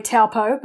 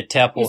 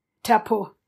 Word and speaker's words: Taupo: I keep saying, I'm going to say it the Aussie way, Taupo, but, of course, that is not how you pronounce it. – Yeah, Taupo. – Is it? Taupo.